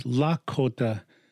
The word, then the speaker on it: Lakota: And to pronounce this correctly is Lakota, Lakota, not Lakota or Dakota or Minnesota. It's Sota. So it'd be Lakota, right? It means something when you say it that way.